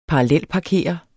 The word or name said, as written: parallelparkere